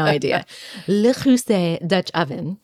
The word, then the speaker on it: Le Creuset: A Le Creuset Dutch oven. – I feel like you pronounced that really well.